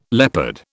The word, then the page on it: leopard: British English